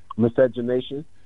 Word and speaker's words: miscegenation: Miscronation? What was that? – Miscegenation. – Miscegenation? – Yes.